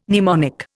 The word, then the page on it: mnemonic: Indian